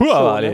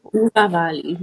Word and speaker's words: Hurawalhi: Hurawali. Ne? – Hurawali, genau.